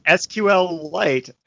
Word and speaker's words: SQLite: So it is pronounced SQLite,